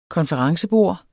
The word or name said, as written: konferencebord